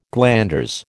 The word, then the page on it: glanders: American English